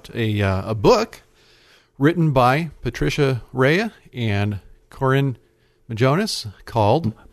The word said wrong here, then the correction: Moganis: Moganis. I believe she pronounced it Moganis.